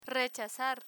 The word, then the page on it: rechazar: Spanish